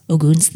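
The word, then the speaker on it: Ōgun: And it is apparently pronounced Ogun. – So I've been saying Ogun since I read this miniseries. I'm going to try to say Ogun, but I'm probably going to slip up.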